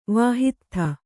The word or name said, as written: ವಾಹಿತ್ಥ